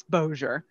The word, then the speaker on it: Bossier: And, like, there's Shreveport and Bossier, which will be pronounced Shreveport, and Bossier. It's like, no, honey, that's where the French doesn't kick in. It's Bossier. Thank you.